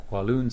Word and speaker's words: Kowloon: You have the Kowloon side, which is spelled K-O-W-L-O-O-N, and then you have Hong Kong Island side.